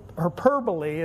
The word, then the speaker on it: hyperbole: And it's a hyperbole is how you say the word.